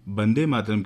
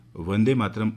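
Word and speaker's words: वंदे मातरम्: इसे संस्कृत भाषा के व्याकरण की दृष्टि से इसे वंदे मातरम की जगह वंदे मातरम उच्चारित करना ही सही इसका उच्चारण है, जिसका अर्थ होता है माता की वंदना करना। देवनागरी लिपि में भी इसे वंदे मातरम लिखा और पढ़ा जाता है और इसका सही उच्चारण भी यही है।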